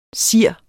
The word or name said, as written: SIRS